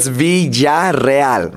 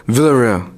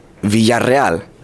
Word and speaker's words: Villarreal: So yeah, there's two L's, and the two L's is a ya sound, and the two R's is a rrr sound. So it's Villarreal, right? – Ooh, that's my last name. So don't say Villarreal, because that's not the right way to say it.